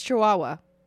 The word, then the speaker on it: Chihuahua: And then it's really close to, how do you say that? – I think it's Chihuahua.